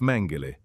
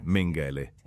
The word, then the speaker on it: Mengele: Ho ascoltato un nastro con la pronuncia tedesca appositamente per il suo nome, ed è Josef Mengele, non Mengele.